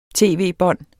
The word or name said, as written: tv-bånd